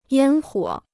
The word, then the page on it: 烟火: yān huǒ